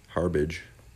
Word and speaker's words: Harbaugh: Harbach? Harbage? Harbage. H A R B A U G H.